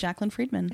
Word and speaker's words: Jaclyn Friedman: How do you say your name? Jaclyn Friedman? – That is correct, Jaclyn Friedman. – I said it. I just said it right off the bat like that. – You said it perfectly. Just as long as you don't call me Jackie, we're good.